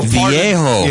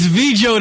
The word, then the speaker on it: Viejo: Viejo is Spanish, of course. – Is that how it's spelled? V-I-E-J-O? – I thought it was American. – V-I-E-J-O?